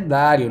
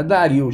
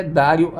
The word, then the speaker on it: Dario: Aliás, notem que a pronúncia é Dario, não é Dario. Já vi muita gente falando Dario Argento, mas o nome correto, né? A pronúncia italiana é Dario Argento.